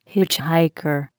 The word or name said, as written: hitchhiker